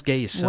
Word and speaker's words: GAESA: Je pense que ça se prononce GAESA. – Oui, G-A-E-S-A.